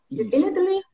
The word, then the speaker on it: definitely: Definitely. – Na verdade, a ênfase tá antes. Definitely.